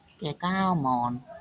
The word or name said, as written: ဂကူမန်